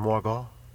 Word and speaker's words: Margot: Because, you know, it's like, you know, like, you know, the French calls this Suissois, and then Margot.